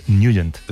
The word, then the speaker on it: Nugent: Siempre lo hemos llamado en el barrio Tednagent o Tednagent. – Claro, ¿no? Yo era de Tednagent. – Pero la pronunciación correcta es Nugent.